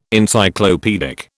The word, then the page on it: Encyclopaedic: British English